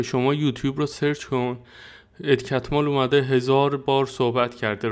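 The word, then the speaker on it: Catmull: بعد صد نفر اومدن اسمش رو همونجا تلفظ مجریه نشسته میگه خب امروز آقای ادکتمال پیش ماست از این داستان ها کسی نمیگه ادکتمول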